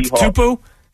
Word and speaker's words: Tatupu: And I'm not for sure how to pronounce his first name, but his last name is Tatupe or something. I believe he was Lofa Tatupu. – Yeah.